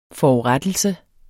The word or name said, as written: FORURETTELSERNES